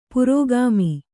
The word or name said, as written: ಪುರೋಗಾಮಿ